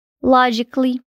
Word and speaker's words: logically: Começando com a letra A, ela não tem som em palavras que terminam com ali, a-L-L-Y. Alguns exemplos são logically, musically e physically.